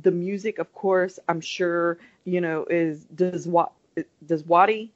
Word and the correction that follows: Djawadi: is that how you say it? – Djawadi.